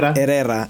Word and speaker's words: Herrera: It's Hector, yeah. Herrera is how you say his name.